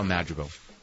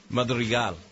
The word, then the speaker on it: Madrigal: We're talking to Al Madrigal. Am I pronouncing it right? – No. – Madrigal. – Yeah, Al Madrigal. Do like the Mexican way. – Madrigal.